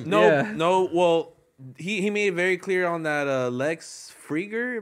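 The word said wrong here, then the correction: Fridman: Am I saying that right? That, like, Friedman.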